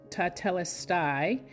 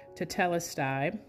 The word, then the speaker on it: tetelestai: And if I can pronounce that, it says tetelestai. And let me spell that is T-E-T-E with an accent mark, L-E-S-T-A-I, tetelestai.